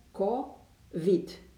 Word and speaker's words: COVID: COVID se pronunță COVID.